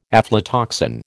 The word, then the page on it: aflatoxin: American English